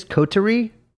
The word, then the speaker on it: coterie: She was part of this sort of, how do you say this? Coterie? C-O-T-E-R-I-E. – Ooh, a coterie.